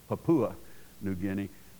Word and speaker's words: Papua New Guinea: Now, he mispronounces it, of course. It's Papua New Guinea.